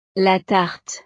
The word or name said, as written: la tarte